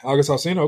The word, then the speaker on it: August Alcina: Was it Alcina? Is that how you pronounce his last name? – Yeah, August Alcina.